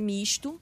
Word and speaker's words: Mixto: É Misto, mas aí o Thiago Muniz fez questão de escrever Misto com S para saber como é que pronuncia.